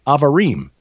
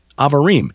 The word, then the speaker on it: Abarim: it's spelled A-B-A-R-I-M, pronounced Avarim. Avarim.